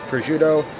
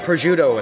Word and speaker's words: prosciutto: And the only reason I think I'm going to get the chef selection, but prosciutto is such a big draw to me, or as your people say it, prosciutto, because you put vowels in everything that you don't pronounce for some reason.